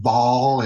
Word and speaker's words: Baal: But the name Baal, some people pronounce it Baal. It's B-A-A-L. Whether you pronounce it Baal or Baal, it is the same thing.